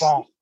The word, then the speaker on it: Rousseau: Rousseau. That's right. Thank you. You say it right.